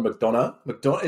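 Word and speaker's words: McDonagh: But no, I think, well, the director, John McDonough. Is that how you pronounce it? – Yep. – Yeah, yep, that sounds good to me.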